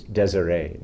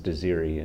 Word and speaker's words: Desiree: It was Desiree, but it was pronounced Desiri in, in Cornwall.